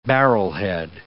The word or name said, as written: barrelhead